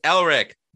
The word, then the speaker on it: Elric: I'm actually a fan of Michael Moorcock, especially the Eric of however it's pronounced, Elric, the albino owner of Stormbringer, the Black Blade.